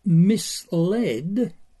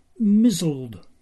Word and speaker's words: misled: The sci-fi author Theodore Sturgeon created a character, also a bookish kid, who first saw the word misled, M-I-S-L-E-D, in an advertisement somewhere and pronounced it to his infant self as mizzled.